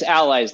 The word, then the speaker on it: Alliyz: Allies is spelled a little odd. It looks like ally is. So people pronounce it differently, but it's Allies, like a comrade or a friend or a supporter.